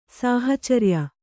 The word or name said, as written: ಸಾಹಚರ್ಯ